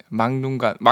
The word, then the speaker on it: 맑눈광: 막눈광 이렇게 하면 안 되고? 네, 막눈광 막눈광이 맞죠? 정확한 발음.